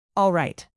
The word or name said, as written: alright